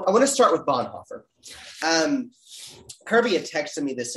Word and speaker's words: Bonhoeffer: Bonhoeffer.